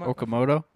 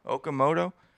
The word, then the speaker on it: Okamoto: How do you pronounce his last name? – Okamoto. – Okamoto.